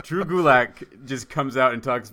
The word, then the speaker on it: Gulak: Gulak. – Gulak.